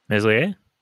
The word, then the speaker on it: Meslier: Meslier? – That's it. Meslier made a big save and then, you know, Leeds took it down the other end and scored.